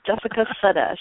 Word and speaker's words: Jessica Sutta: It's Jessica Sutta.